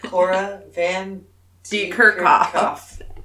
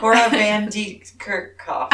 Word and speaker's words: Cora van de Kerkhoff: Cora van de Kerkhoff. – Cora van de Kerkhoff. – I know, it does not roll off the tongue. – Cora van de Kerkhoff. Say it like you're Dutch.